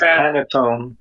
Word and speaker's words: Panettone: I think you're saying it wrong. – Okay. – Because Pantone isn't Pantone the color palette? – No. – Yeah, Pantone is a color palette. – Okay. No, but I think it's Panettone. – Panettone is a color palette, no? – You need the little fucking things over the fucking letters to get it right? You're sure? – No, bro, you just need to pronounce it properly.